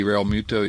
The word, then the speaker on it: Realmuto: How do you pronounce his name? Realmuto or JT Realmuto? Yes.